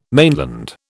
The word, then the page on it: mainland: British English